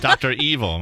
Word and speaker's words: Dr. Evil: Ah, and it was sold to a guy, Dr. Avil? – $1 billion. – Oh, Dr. Evil, my bad. I mispronounced that there.